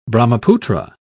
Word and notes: brahmaputra: British English

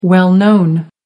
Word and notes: well known: American English

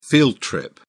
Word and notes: field trip: British English

